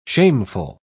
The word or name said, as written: shameful